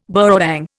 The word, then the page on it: burrawang: Indian